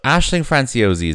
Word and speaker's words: Aisling Franciosi: So Aisling Franciosi is in this movie. – Yes, she is. – What could this be? Interesting. – Interesting. Okay, we have six names left. She's seventh billed, Aisling Franciosi.